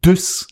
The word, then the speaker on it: Dusse: Nämlich Carsten Düss wird er ausgesprochen, geschrieben Dusse. Carsten Düss. – Mit ähm, C, ne? – Carsten. D-U-S-S-E wird er geschrieben.